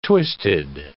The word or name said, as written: twisted